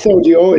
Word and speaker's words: Sodyo: I was going to ask you, so did I pronounce it right? Is it Sodio? How do you say it? – Yeah, it's Sodio. And actually, Sodio, it's two words in Hebrew, that it's Sod and Dio, which is secret ink.